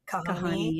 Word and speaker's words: Kahani: I know you have an organization, hopefully I'm saying it correctly, Kahani. Is that the correct? – Kahani. Yeah.